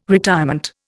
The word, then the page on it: retirement: Indian